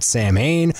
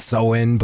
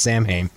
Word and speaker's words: Samhain: I loved Samhain, which is how you pronounce that band. Glenn Danzig knows it's Soin, but the band is pronounced Samhain.